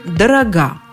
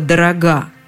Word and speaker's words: дорога: как ведет себя ударение в кратких формах прилагательного «дорогой». Хлеб дорог, молоко дорого, все фрукты очень дороги. Но вот сметана, например, дорога. Да что там сметана и губная помада дорога, и пудра дорога список можно не продолжать.